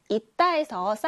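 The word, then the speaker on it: 있다: 이따에서 쌍시옷은 이렇게 디귿의 소리가 나요. 그래서 이따라고 읽어줘야 합니다.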